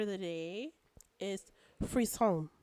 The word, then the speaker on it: frisson: Frisson. – Frisson. Okay. That's really how you say it, frisson. – Okay, for sure. – It's spelled F-R-I-S-S-O-N. I almost said F-R-E-E.